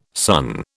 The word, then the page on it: son: British English